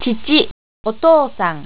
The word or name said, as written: chichi